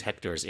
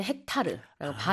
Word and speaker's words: hectares: Oh, you guys do use hectares in Korean. – 근데 우리는 헥타르라고 발음이 달라요. – Okay. – Pronunciation이 좀 달라.